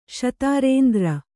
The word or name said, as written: ಶತಾರೇಂದ್ರ